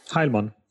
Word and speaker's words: Heilmann: How do you pronounce your name? – Heilmann. – I got close.